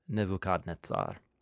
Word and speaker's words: Nebuchadnezzar: Nebuchadnezzar. And actually, that's a Hebrew pronunciation. – Of a Babylonian name.